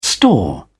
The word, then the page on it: store: British English